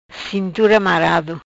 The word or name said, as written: Marado Cintura